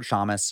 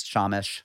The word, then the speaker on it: shamus: Same word, shamus, shamish, depending on your pronunciation.